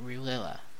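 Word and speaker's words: R'lyeh: I want to make sure I pronounce it Rilila.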